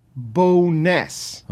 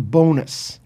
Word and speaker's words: Bowness: And it was Cassie Campbell's pronunciation of Rick Bowness. Bowness. – Okay. – As opposed to bonus.